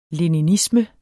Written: leninisme

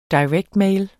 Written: mail

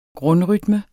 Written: grundrytme

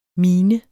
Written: minens

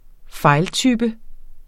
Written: fejltype

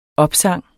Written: opsang

